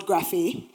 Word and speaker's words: graphe: So the first word is the word graphe, right? And it is spelled G-R-A-P-H-E with the slight accentuation at the top, right?